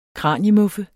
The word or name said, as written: kraniemuffe